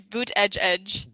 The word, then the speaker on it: Buttigieg: Four different ways. Either you can think of it as either as Buttigieg, okay. Buttigieg, Buttigieg, Buttigieg. Buttigieg. So those are like the four different pronunciation options he gave on Twitter.